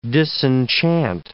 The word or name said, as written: disenchant